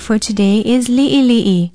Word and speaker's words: li'ili'i: Be sure to include the diacritical pronunciation mark, the backwards apostrophe called the okina, and spell it L-I-Okina-I, L-I-Okina-I. It means small, little, in bits, or few. If you spell it right with the diacritical pronunciation marks, it is easier to pronounce li'ili'i.